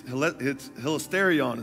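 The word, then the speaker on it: hilasterion (ἱλαστήριον): Propitiation in the Greek, it's hilasterion is how you say it.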